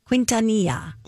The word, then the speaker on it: Quintanilla: This is the story of Tejano singer Selena Quintanilla, I think is how you say her name. Quintanilla.